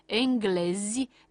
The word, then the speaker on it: englezi: În această situație suntem puși în fața alternanței fonetice dintre literele Z și J, iar formele corecte de plural ale acestor cuvinte nu sunt cele cu J, așa cum am pronunțat mai devreme, ci cele cu Z, adică francezi, englezi, danezi, finlandezi, japonezi, chinezi.